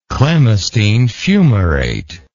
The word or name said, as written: clemastine fumarate